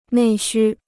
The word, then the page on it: 内需: nèi xū